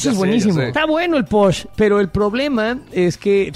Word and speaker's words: pox: Es P-O-X, se escribe posh.